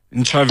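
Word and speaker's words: Ntshavheni: Minister of Presidency is a Minister Machinveni. Did I pronounce it right? – Nchaveni. – Did I pronounce it right? – Minister in the Presidency, Nchaveni, yes.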